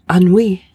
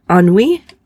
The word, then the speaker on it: ennui: And it is pronounced Anui. I must have seen a comedic like play on this word because I can't get Anui out of my head. But Anui is how it's pronounced.